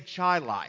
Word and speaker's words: Chili: Now, get this it's spelled Chili, but they say Chilai.